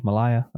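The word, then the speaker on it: Myha'la: Am I saying that right? M Y H A dash L A Malaya.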